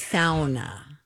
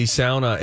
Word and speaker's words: sauna: I also took a lot of crap for my mispronunciation of sauna. – Yes. – It's sauna. – You know, it is technically sauna,